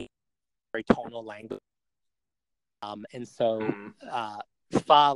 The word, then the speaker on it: pho: It's pho, like fun, with the N taken off the end of it. – You sure it's not pho? – I don't know what that is.